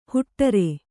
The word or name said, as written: ಹೊಟಾರ